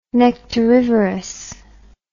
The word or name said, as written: nectarivorous